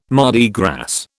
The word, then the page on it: Mardi Gras: British English